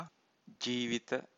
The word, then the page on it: jivita: Pali